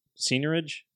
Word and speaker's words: seigniorage: The financial terms and stuff like seigniorage. Am I even saying that right? – Yes.